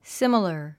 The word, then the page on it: similar: símələ r; スィマラァ